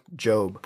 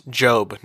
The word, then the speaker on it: Job: And that is the book of Job. – Pronounce Job, not Job. – Not Job. Job, J-O-B, is part of the poetry and wisdom literature of the Bible.